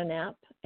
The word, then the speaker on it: Knapp: It's TU Christina Knapp, and Knapp is spelled N-A-P-P. TU is P-I-I-U.